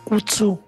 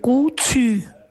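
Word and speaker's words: KuToo: Non si dice Kutsu, si dice Kutsu.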